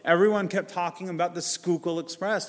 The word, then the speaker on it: Schuylkill: It's pronounced Schuylkill, like school or scuba or whatever, but Schuylkill, scooter, but Schuylkill. And I said, but there's a Y in it.